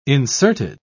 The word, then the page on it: inserted: British English